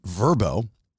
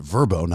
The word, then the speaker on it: Vrbo: We're selling Paradise City, and Airbnb and Vrbo, as I've now learned, it's pronounced Vrbo, not VRBO.